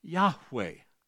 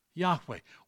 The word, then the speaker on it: Yahweh: And they are the ones who told us when they added vowel pointing to the Hebrew text that it was indeed pronounced Yahweh. Yahweh.